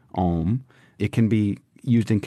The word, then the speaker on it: Om: Om is an ancient and sacred word from Eastern spiritual and yogic traditions, including Hinduism and Buddhism. It's pronounced Aum, A-U-M,